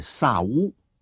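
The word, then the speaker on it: 萨 乌 (שָׁאוּל): 扫罗这个字的原文发音比较像是萨乌，所以这个小男孩的名字取自以色列第一个王，都属于同一支派，就取名为扫罗。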